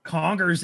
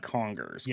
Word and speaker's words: Congers: Congers is also in New York.